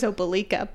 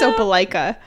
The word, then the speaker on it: Opelika: I know how it's said right now, and I feel like this would be a good time to also mention that I fucked up the pronunciation of Opelika. Oh yeah, that we thought that it was Opelika, but yeah. it's Opelika.